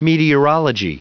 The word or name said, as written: meteorology